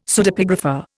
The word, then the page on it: Pseudepigrapha: Indian